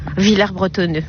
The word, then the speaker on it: Villers-Bretonneux: Je viens à Villers-Bretonneux depuis plus de 20 ans. – Vous savez qu'on prononce pas le S ici. Hein. – Villers-Bretonneux, pardon.